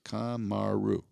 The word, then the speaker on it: Kamaru: learn this three-syllable name. Is it? Yeah, three, Kamaru. Yeah, three syllables.